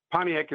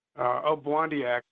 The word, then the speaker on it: Obwaandi'eyaag: Well, I think, first off, I wanted to share with you that Pontiac is more the anglicized pronunciation of his name. Obwandiak is probably more closely to the way His name was stated and remembered, and it meant he who travels or he who is traveling.